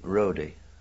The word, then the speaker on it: Rodi: Rodi. R-O-D-I, I think they spell it.